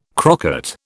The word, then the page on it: crocket: British English